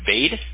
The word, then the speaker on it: Bade: B-A-D-E. – B-A-D-E. – Boy, Apple, David Edwards. – Is it pronounced Bade? – Bade, but Bade? – Yeah. I was going to say Batty at first, but Bade, that makes more sense. – It depends which relative you're talking to, so we all have different ways to pronounce it.